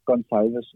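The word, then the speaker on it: Gonçalves: altså, øh, han, han udtales Gonsalves, og det er bjerg, jeg kommer til at dø på.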